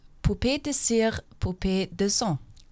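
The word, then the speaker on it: Poupée de cire, poupée de son: Keine Garantie für die Aussprache. Wäre von France Gall Poupée de Cire, Poupée de Sang. – Das hört sich richtig, richtig an.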